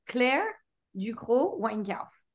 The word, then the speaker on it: Claire Ducrocq Weinkauf: Wait, it's Weinkauf. Did I say it right? No. You say your name so I can say it right. – All right. Claire Ducrocq Weinkauf.